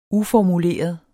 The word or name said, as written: uformuleret